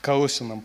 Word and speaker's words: Колоссянам: Колоссянам первая глава, Колосинам, правильно, ударение, Колосинам первая глава,